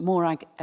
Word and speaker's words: Morag: My name is Miss Morag Ellis. Christian name is spelled M-O-R-A-G.